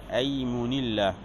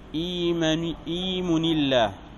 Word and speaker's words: أيمن: بفتحهما، أيمن الله بفتح الهمزة، وكذلك الغلام بالفتح، أو بكسر في إيمن الله بكسر الهمزة.